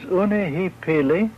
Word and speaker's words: unihipili: For instance, the word for the subconscious, the grasshopper, is pronounced in two ways, unihipili and unatihili.